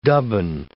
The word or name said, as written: dubbing